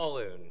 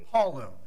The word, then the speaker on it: Paulun: Paulun. – Paulun. Okay. – Like the boy's name Paul, and then it rhymes with typhoon is my okay.